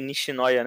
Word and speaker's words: Nishinoya: Pra, por exemplo, o Líbero lá, é, o, é Nishinoya, né?